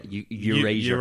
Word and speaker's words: Erasure: Which I constantly get pulled up on the way I pronounce it. – Because I put a U in it. – Yeah, Erasure.